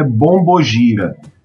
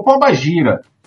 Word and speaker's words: bombogira: E aí, se, se você está me ouvindo aqui com essa pronúncia terrível, você deve estar tá falando, ah, ele falou pombagira. Não, eu falei bombogira.